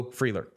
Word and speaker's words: Freeler: it would be spelled Beau, F-R-E-E space L-E-R, Beau Freeler. Got it. That's how you pronounce it.